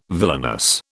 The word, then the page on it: villainous: British English